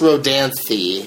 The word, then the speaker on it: Rodanthe: And it's pronounced Rodanthe. – Rodanthe. – So, thee. Thee. You could go something with that, with a thee sound. Thee. – Rodanthe. – Like theory, if there's a movie that started with theory.